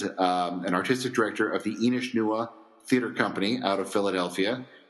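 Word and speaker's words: Inis Nua: And it's Inishnewa, by the way. – Inishnewa, pardon me. I normally say it right, and then this time I didn't.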